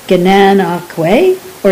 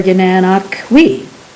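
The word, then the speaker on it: Gananoque: I did, though, after bringing a smile to the attendant's face with my awkward pronunciation, learn the two correct ways to pronounce Gananoq. Gananoqwe or Gananoqwe. He told me it was an Indian name, meaning rocks rising out of the water.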